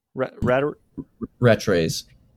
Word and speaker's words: Rattray's: We got an awesome, I think it's pronounced Rattray's. – Rattray's.